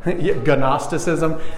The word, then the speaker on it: Gnosticism: Starts with a G, but it doesn't actually sound that way. Gnosticism. The G is silent.